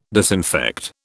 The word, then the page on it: disinfect: British English